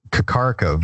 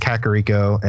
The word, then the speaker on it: Kakariko: I know it's probably true now, but I used to call this place Kakariko Village, believe it or not. I don't know why I settled on that pronunciation, but it took me years to figure out that it was Kakariko and then pronounce it correctly and break myself from calling it Kakariko.